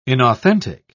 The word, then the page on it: inauthentic: British English